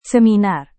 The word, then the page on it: seminar: Indonesian